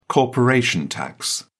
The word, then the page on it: corporation tax: British English